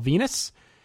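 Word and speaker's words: Venis: Tyson Tomko defeated Val Venus. Rhymes with penis.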